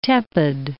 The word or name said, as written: tepid